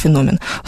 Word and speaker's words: феномен: Если хотите быть грамотными, тогда говорите, конечно, феномен. Слово греческое, никакого французского происхождения у него нет, поэтому ударение на второй слог.